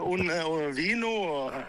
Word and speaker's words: vino: un vino. – Un vino. Ja. Alle V-er i Spania uttales som B.